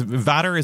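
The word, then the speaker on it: VATER: it's Vatter is the pronunciation? – Yes.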